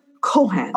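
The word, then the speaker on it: Cong: you know, the tavern that was, or the Kong. Yeah. – Yeah. The bar, the pub that wasn't really a pub. – Here we pronounce it Cohan.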